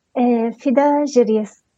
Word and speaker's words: Fida Jiryis: Fida Jiryis. – Jiryis. – Jiryis. – Yes, as okay. It's written, Jiryis, yes. It's a bit of an odd name to pronounce in English.